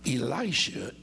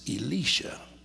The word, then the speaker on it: Elisha: And so this woman, notice that the man of God, Elisha is his name as we pronounce it in English, but in Hebrew it's Elisha. And so whether you say Elisha or Elisha, he is the prophet that God is using in this situation.